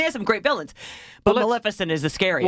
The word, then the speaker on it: Maleficent: Maleficent is a threat to everyone.